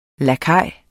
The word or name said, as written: lakaj